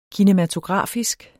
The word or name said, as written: kinematografisk